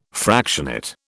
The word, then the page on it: fractionate: British English